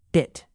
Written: bit

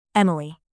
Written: emily

